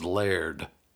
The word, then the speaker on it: Laird: There's no doubt how to pronounce Laird. You look at it. It's L-A-I-R-D. And we're all familiar with the word air. And it's right there in the middle, air. So you say air.